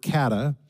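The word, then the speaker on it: kata (κατά): And when you read this in the original Greek text of the New Testament, the words according to is the Greek word kata. It's spelled K-A-T-A.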